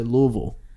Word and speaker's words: Louisville: Say Louisville again. I like Louisville. That's how they say it there.